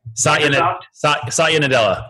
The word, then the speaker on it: Satya Nadella: So I'm going to make a fool of myself because I can't remember how to say his name, but it's like Satya Nadella from Satya Nadella.